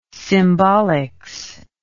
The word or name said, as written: symbolics